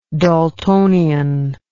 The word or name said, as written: Daltonian